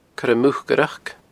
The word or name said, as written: coiremucaireachd